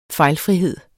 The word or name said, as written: fejlfrihed